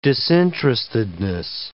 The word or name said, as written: disinterestedness